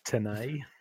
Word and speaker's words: Tenet: I believe it's pronounced Tenet. – Oh, okay, whoops.